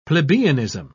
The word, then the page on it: plebeianism: British English